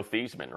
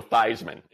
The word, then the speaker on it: Theismann: Do what Notre Dame did for Joe Theismann, right? And literally change the pronunciation of the man's name to Joe Theismann instead of Theismann.